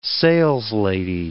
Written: saleslady

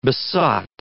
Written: besot

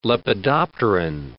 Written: lepidopteran

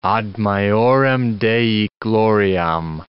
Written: ad majorem Dei gloriam

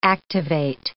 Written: activate

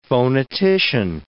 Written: phonetician

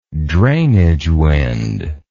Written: drainage wind

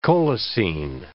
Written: colicine

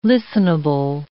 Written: listenable

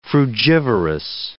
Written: frugivorous

